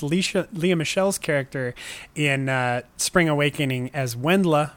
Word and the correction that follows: Wendla: but it is Vendla. Because it is a German name,